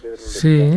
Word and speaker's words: Lezcano: Y hay un poeta, es que Pedro Lezcano, ¿lo digo bien? Sí, Pedro Lezcano.